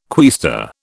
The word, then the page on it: quaestor: British English